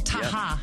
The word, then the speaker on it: Taha: Oh, that one's Rashid Tahar? – Yes. – Pronouncing that correctly? – Taha.